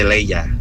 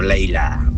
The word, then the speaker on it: Leia: Se dice Leia, no Leila.